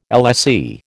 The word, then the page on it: LSE: American English